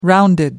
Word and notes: rounded: American English

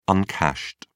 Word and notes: uncashed: British English

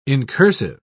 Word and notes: incursive: British English

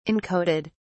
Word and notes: encoded: British English

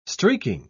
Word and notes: streaking: British English

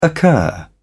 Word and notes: occur: British English

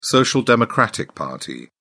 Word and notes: social democratic party: British English

